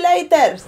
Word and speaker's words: later: Continuamente escuchamos see you later. – Later. – Later? – Later. – No se dice así, se pronuncia later, later, later. – Later. – Later. – Con la schwa al final, later.